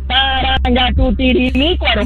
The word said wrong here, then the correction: Parangaricutirimícuaro: Dice Parangacutirimícuaro y es Parangaricutirimícuaro. – Le falta, falta una sílaba.